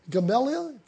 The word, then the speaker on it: Gamaliel: He was taught by a guy called Gamaliel. It's G-A-M-A-L-I-E-L. Gamaliel, I think is the way you pronounce it.